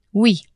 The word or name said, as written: oui